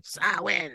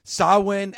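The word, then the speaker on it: Samhain: Got to say it like Jim Mitchell. Sawin. – All right. Sawin.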